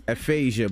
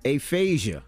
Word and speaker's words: aphasia: Spell it to the people. – All right. – A-P-H-A-S-I-A. – Yep. Aphasia. – Aphasia. I think it's aphasia.